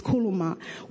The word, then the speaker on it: keleusma: The Greek word for what God is talking about is kelema, which is K-E-L-E-U-S-M-A. But the S is silent. It's kelema, which means a word of command.